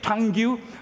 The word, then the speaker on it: tongue: Some of the English words are so difficult to pronounce that my tongue has real difficulty. What do you say? Tongue? T-O-N-G-U-E. No, my friend, you don't pronounce the last two letters, U-E. My tongue has difficulty.